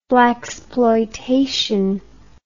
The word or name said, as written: blacksploitation